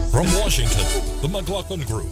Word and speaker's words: McLaughlin: McLaughlin.